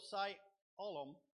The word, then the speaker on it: Siloam: It's not Siloam, it's Siloam. Siloam.